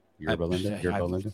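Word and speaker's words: Yorba Linda: Yerba Linda? – Sounds okay.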